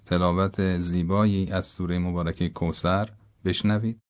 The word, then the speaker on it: کوثر: اگه سین رو توک زبونی تلفظ بکنیم، یعنی یه مقدار نوک زبان اون بین دندونهای بالا و پایین قرار بگیره، حرف سه سه نقطه به راحتی تلفظ میشه. مثلا کوثر به جای کوثر، نوک زبان یه مقدار که از بین دندونها بیاد بیرون تلفظ درسته. انا اعطینا کل کوثر، کوثر، به همین راحتی.